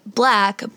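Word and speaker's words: 6LACK: His name's Black. He spells it C-L-A-C-K, or the number six, sorry, six L-A-C-K. But he pronounces it, you know, Black, but people make fun of him and call him Slick Slack.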